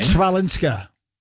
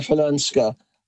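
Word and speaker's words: Chwalińska: Oh, go on, try pronouncing her name. – Svalinska. – Well, you're close. There've been a couple of variations on the pronunciation, but I'm going with Maja Falanska.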